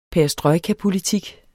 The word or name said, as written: perestrojkapolitik